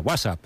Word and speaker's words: WhatsApp: No, peor aún, con acento en la primera, WhatsApp, ¿no?